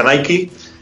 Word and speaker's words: Nike: Es decir, esa fusión entre el, deportista de élite Michael Jordan y la compañía de zapatillas, eh, aquí decimos Nike, pero en Estados Unidos se pronuncia Nike,